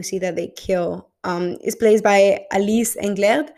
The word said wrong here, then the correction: Englert: Or why am I pronouncing it French when she's American? Oh, Australian, actually. Oh, it's great. Alice Englert.